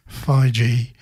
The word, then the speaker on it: Fygi: That's she's Dutch, and that's how you pronounce her surname, F-Y-G-I, Feige.